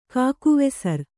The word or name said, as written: ಕಾಕುವೆಸರ್